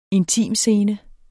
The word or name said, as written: intimscene